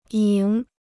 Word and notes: 赢: yíng

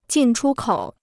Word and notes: 进出口: jìn chū kǒu

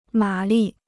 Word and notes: 马力: mǎ lì

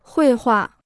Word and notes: 绘画: huì huà

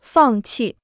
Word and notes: 放弃: fàng qì

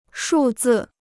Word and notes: 数字: shù zì

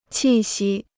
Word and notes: 气息: qì xī

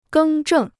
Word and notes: 更正: gēng zhèng

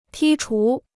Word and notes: 剔除: tī chú

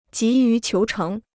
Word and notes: 急于求成: jí yú qiú chéng

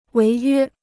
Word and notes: 违约: wéi yuē

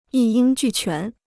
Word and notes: 一应俱全: yī yīng jù quán

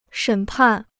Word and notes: 审判: shěn pàn

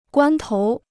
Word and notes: 关头: guān tóu

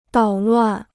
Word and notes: 捣乱: dǎo luàn

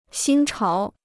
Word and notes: 新潮: xīn cháo

